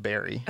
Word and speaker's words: Barre: It's spelled Barre, but it's pronounced Barrie.